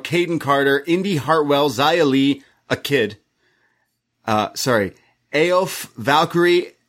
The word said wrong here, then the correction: Aoife: Aoife. – Aoife. – Yeah, Valkyrie. – I think Regal messed up. I'm pretty sure that's pronounced Aoife.